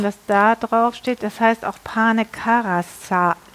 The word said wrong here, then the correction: Carasau: Carasau. Carasau. Carasau. ja. – Wie Curacao.